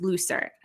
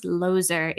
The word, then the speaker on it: Looser: and her last name is not actually pronounced Looser. I think it is pronounced Loser, if I understood correctly, from the Jasna Boise event that I went to where she actually gave a talk.